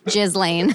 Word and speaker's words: Ghislaine: Those are your is that how you pronounce her name? – Yes. – No, you pronounce it Ghislaine. I'm that's positive. It's definitely pronounced Ghislaine.